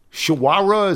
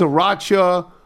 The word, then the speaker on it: Sriracha: I brought the shawara. Is that how it's pronounced? – Sriracha? – Sriracha.